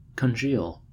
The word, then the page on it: congeal: American English